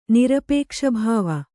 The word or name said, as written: ನಿರಪೇಕ್ಷಭಾವ